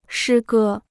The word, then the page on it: 诗歌: shī gē